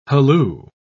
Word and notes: halloo: British English

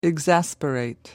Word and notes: exasperate: American English

